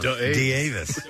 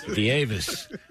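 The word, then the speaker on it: D'Avis: But anyway, also we will have a block of corn for Jonathan DeVavis, and it's also, it's spelled D apostrophe Avis, like wow. Avis Rent-A-Card. – Yeah. D-Avis. – D-Avis.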